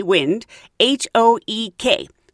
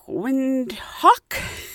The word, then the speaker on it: Windhoek: It's W-I-N-D, wind, H-O-E-K. So I knew I was probably saying it wrong. I was like, Windhoek? Well, I met a lot of nice people on the plane ride from Johannesburg to what I found out is Windhoek. Windhoek, not Windhoek, and it means windy corner.